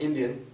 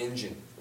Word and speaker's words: Indian: It's pronounced Indian, not Injun.